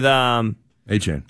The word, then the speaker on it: Achane: With Achane. – Travis Achane. I can't understand that that's how it's pronounced these days. – Yeah. – After it being ETN, forever.